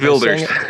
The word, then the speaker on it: Wilders: And even in the Netherlands, we've got Geert, how do you say his name? Geert de Villiers? – Geert Wilders. – Geert Wilders.